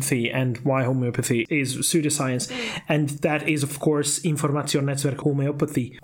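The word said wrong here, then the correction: Informationsnetzwerk Homöopathie: Can you help me out with that pronunciation, please? – Yeah, so in German, it's Informationsnetzwerk Homeopathie. – Oh, it sounds much better.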